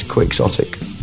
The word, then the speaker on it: quixotic: The temptation is always to pronounce it Quixotic after Don Quixote, which is okay. Where the word comes from. But I believe the correct pronunciation is Quixotic.